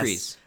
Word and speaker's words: Kryze: And I did look it up, and it is Kryze, but everybody calls her sister Satine Kryze.